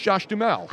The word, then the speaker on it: Duhamel: I even said it's Josh Duhamel, even though it's spelled Duhamel, D-U-H-A-M-E-L. But it's Josh Duhamel.